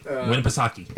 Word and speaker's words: Winnipesaukee: Winnipesaukee.